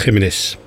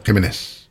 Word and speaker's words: Jiménez: Flaco Jiménez. Zo moet je het uitspreken, hè, geloof ik. – Ja, ja, Jiménez. – Jiménez.